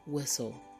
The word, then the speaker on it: whistle: Here are some more words that start with WH, where the H is silent. We have white and whistle. Say the words after me. White, whistle. Did you hear the H sound? No, because the H sound is silent.